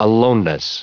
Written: aloneness

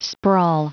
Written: sprawl

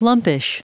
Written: lumpish

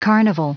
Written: carnival

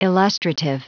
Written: illustrative

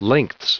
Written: lengths

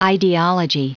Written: idealogy

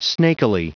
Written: snakily

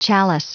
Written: chalice

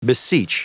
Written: beseech

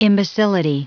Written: imbecility

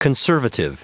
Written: conservative

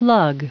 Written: lug

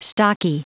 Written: stocky

